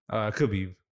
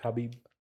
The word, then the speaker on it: Khabib: Khabib, for sure. – It's pronounced Khabib. – I think it's Khabib. – It's Khabib. – No. It's pronounced. You don't say the K.